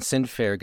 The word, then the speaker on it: Sundfør: Susanna Sinfair gets actually spelled S-U-S-A-N-N-E, so it looks kind of like Suzanne, but it's pronounced Susanna. And Sinfair gets S-U-N-D-F, and then basically an O with a slash through it, R. So it looks like Sun DeFore, but it's Sinfair.